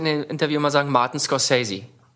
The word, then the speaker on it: Scorsese: Scorsese gesagt. – Ich hätte auch Scorsese gesagt und nicht Scorsese, aber vielleicht.